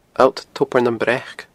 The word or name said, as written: Allt Tobar nam Breac